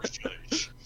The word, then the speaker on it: Zhuszh: One of the character arc for Rena Kalinacom is going to be discovering what Zhuszh means. Into the quest for Zhuszh. – Oh, that's how you pronounce that, okay.